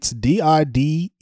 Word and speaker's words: Diderot: Dietrich, is that what it is? – No, it's Diderot.